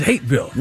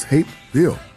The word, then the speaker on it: Hapeville: How come they don't pronounce the P? – They do. – It is Hapeville. – No, it is Hapeville.